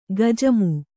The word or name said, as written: ಗಜಮು